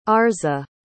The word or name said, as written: Arza